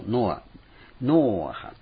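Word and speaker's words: ノアハ (נֹחַ): と言って、その子をノアハって発音するんですね。ナーハムという慰めるという言葉から作られた。ノアハって言うんです。ノアハ、ノアと慰めっていう言葉だったんですね。まあ、そのノアハ、ナーハムがここで使われているわけです。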